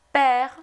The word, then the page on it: père: French